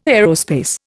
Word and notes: aerospace: Indian

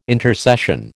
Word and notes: intercession: American English

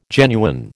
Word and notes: genuine: American English

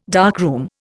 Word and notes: darkroom: Indian

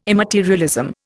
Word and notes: immaterialism: Indian

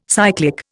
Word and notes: cyclic: Indian

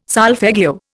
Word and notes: solfeggio: Indian

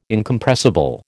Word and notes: Incompressible: American English

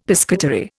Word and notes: piscatory: Indian

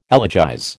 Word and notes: elegize: American English

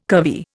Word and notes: curvy: Indian

